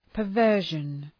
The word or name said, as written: perversion